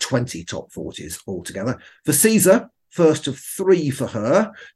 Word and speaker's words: SZA: This is All the Stars by Kendrick Lamar and Caesar. Caesar is spelled S-Z-A, for those of you who don't know.